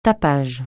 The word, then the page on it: tapage: francais